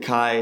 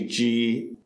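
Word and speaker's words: Kyi: It's K-Y-I. My dad pronounces it Ki, my mom pronounces it Kai. It's actually G in Burma, in Burmese.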